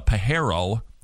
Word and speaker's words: Pharaoh: He's basically kidnapped, sold into slavery, put in jail, and yet he ends up being the right-hand man of the Pharaoh. That's actually how you pronounce it.